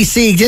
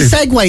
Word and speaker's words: segue: I'd say he seeged into it. – He seeged into it, did he? – Yeah, are you honestly segue? Is that honestly how you would pronounce that? S-E-G-U-E, yeah. Segue.